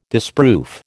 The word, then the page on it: disproof: American English